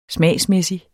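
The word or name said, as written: smagsmæssig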